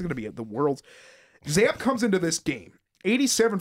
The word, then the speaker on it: Zappe: And you'll probably be able to pronounce, what is it, Bailey Zapp or Zappe, or I think it's Zapp. I don't know. – It is Zapp.